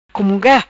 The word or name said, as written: Cumunga